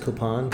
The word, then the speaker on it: Copan: I think it's pronounced Copan.